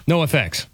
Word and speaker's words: NOFX: NOFX.